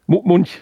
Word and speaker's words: Munch: Ja, Edvard Munch. – Munch, spricht man wirklich Munch, okay.